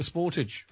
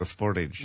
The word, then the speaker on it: Sportage: The most popular actual model in Donegal so far this year, the Kia Sportage, or you might pronounce it differently. – Well, in America, they call it the Sportage. – The Sportage, yeah.